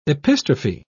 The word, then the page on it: epistrophe: British English